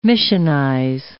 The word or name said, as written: missionize